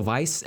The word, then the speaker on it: Weisz: Rachel Weisz, yeah. It throws me off because her last name begins with the W, but it's pronounced with a V, right?